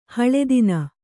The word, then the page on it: ಹಲುದಿನು: haludinu